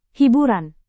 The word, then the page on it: hiburan: Indonesian